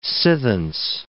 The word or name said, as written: sithence